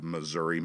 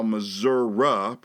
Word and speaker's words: Missouri: they both explained how Missouri pronunciation evolved from a spelling-based English pronunciation of Missouri. Yeah, that's probably how the first English speakers coming into the territory called it, Missouri. Eventually, the finally lightly stressed syllable of I shrunk to a. The U sound is a default vowel for unstressed syllables in the English language, especially older dialects of the English language going back to the British Isles of the 16th, 17th, and 18th century.